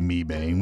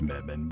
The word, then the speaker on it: Mebane: By the way, before somebody gets online, before somebody goes, I don't know how to pronounce it, we always say Mebane. We know it's Mebane, but it's fun to say Mebane.